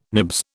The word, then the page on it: nibs: British English